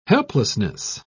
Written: helplessness

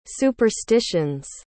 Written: superstitions

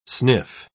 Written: sniff

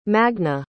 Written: magna

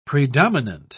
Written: predominant

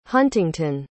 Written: huntingtun

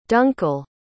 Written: Dunkle

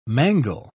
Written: mangle

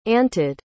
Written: anted

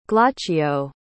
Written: glaucio

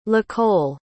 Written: lacole

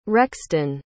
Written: rexton

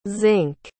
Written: zink

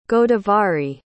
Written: godavari

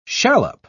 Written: shallop

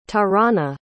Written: tarana

